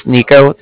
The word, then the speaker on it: Niko: Again, my name's Nico. That's spelled N-I-K-O.